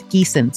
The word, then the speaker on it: Giesen: It's Giessen, spelled G-I-E-S-E-N. Sounds like the geese that maybe you see at the park.